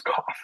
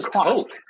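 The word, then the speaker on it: Koch: The biggest news story of the week is that Cook Media is pronounced Cough Media. Did you know that? Cough Media. Cough Media is how you pronounce it. I thought it was Coke. I thought it was like the Coke. – No. I thought it was Cook because like Jim Cook is the president of Boston Beer Company. It's spelled the same way. It's Cough. It's Cough.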